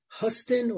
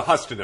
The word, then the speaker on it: Huston: What was the, is it pronounced Huston or Houston plan? – The Huston affair, yeah.